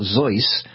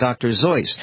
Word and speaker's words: Seuss: Again, the family pronounced it in the German style, Zeus. And to rhyme with voice, that's the way he always said it. And he initially preferred that Dr. Seuss be pronounced Dr. Zeus.